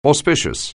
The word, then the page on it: auspicious: anglais